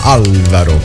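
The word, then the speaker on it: Álvaro: per Alvaro De Luna su Alvaro De Luna, anzi l'accento sulla prima A, Alvaro.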